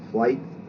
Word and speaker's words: Fleit: My mother's maiden name was Fleet. F-L-E-I-T. Some of the branch pronounce it Flight, and some pronounce it Fleet.